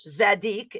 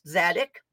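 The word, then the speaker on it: Tzadik: Interestingly enough, the 18th letter of the Hebrew alphabet is Zadik. It starts with a T. The T is silent. Zadik.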